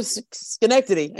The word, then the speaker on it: Schenectady: We got onto Schenectady.